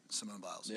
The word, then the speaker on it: Simone Biles: You know, what's relevant to this particular situation is I was watching an interview with Simone Biles. – Mm-hmm. – I think I said that right. Simone Biles. – Yeah.